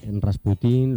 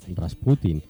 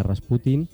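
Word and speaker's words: Rasputín (Распутин): Conocemos Rasputín, lo decimos siempre con este acento agudo, cuando realmente lo que tendríamos que decir es el acento en la llana, Rasputín. – ¿Ah, sí? – Sería, sí, la pronunciación correcta sería Rasputín, pero bueno, vamos a decir Rasputín porque al fin y al cabo es como siempre se, uh-huh.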